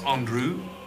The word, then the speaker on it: Andru: More, it's pronounced Andrew. A-N-D-R-U. – A-N-D-R-U?